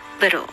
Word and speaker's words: little: リトルは先ほど説明したフラップ T で little になりますね。